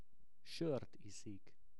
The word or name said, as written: sort iszik